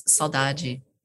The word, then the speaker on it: saudade: The only cognate in the world, 7,000 some odd languages, is the Portuguese saudade, pronounced the Brazilian way.